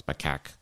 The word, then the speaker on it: macaque: Throngs of macaque monkeys. That is how you pronounce macaque.